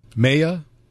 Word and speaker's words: Mei: Oh, it's just May. M-E-I is just May.